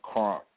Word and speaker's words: Crunk: DJ Hype Man _ Crunk. And the Crunk is spelled C-R-U-N-K.